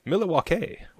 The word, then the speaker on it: Milwaukee: It's pronounced Milwaukee, which is Algonquin for the good land.